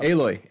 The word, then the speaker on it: Aloy: Alloy. Sorry. – What's the name of the game? – It's Horizon. – Horizon Zero. – Aloy. – Aloy. See, I was saying her name wrong.